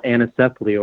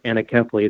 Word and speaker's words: anencephaly: The child died of a condition called anencephaly, or some pronounce it anencephaly.